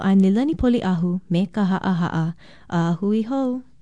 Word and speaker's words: ha'aha'a: Ha'aha'a is spelled with two okina or glottal stops, H-A-okina-A, and pronounced ha'aha'a.